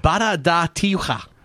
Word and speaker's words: Barra da Tijuca: It's Barra da Tijuca.